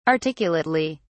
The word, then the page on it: articulately: British English